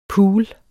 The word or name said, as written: pool